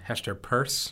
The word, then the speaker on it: Hester Peirce: I would just quickly clarify that it is Hester Pierce is how you pronounce the name.